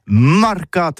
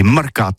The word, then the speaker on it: marcat: Eh bien, d'ici à toutis, le mercat de l'Uni ou le mercat des rebelles, mais en gascogne toulousain, c'est prononcé les marcat, avec un A marquette. En gascogne toulousaine, bah, du côté, par exemple, de l'île des Baches, c'est l'île Jourdain, pour parler du marché, vous prononcez les marcat.